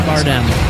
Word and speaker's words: Bardem: Javier Bardem. Is that how you say his name? – Yeah.